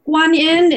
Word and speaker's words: Kuan Yin: Okay, so Kuan Yin is how the name is pronounced in Mandarin Chinese.